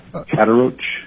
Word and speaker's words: Kadarauch: My name is Joel Kodorich, K-A-D-A-R-A-U-C-H.